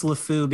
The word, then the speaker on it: LeFou: Did I say his name right? LeFou? I think I messed that up. – LeFou.